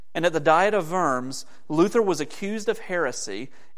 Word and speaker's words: Worms: city of Worms, spelled like worms, but pronounced Worms.